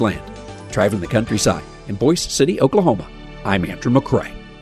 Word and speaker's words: Boise City: And while the county seat's name is spelled the same way as the capital of Idaho, it's pronounced Boise City, the namesake of a local rancher.